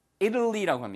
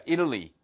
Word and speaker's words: Italy: 발음 하나만 주의하세요. 예. 이탈리아를 예, Italy라고 합니다. Italy. 어? 누구한테 이를 건데요? 자, Italy. 물론 이게 Italian 하고 뒤에 이제 IAN이 붙으면은 발음이 Italian이 되지만 요 국가명은 발음을 거의 미국식 발음은 Italy로 들립니다.